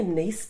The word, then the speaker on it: Nice: She goes, oh, and I said, oh, maybe a niece biscuit. – What are the niece biscuits? – They're the ones with the sugar. – Okay, right. – And because you pronounce it Nice like a French Nice.